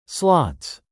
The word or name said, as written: slots